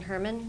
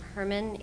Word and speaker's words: Herrman: It's Michelle Allison Herman. That's M-I-C-H-E-L-L-E. Middle name is A-L-Y-S-O-N. Last name Herman, H-E-R-R-M-A-N.